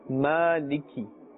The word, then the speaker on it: Maliki: Maliki. You can see the vertical fatha above the meem. Maliki. That kaf with its kasra must be pronounced clearly.